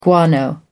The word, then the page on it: guano: American English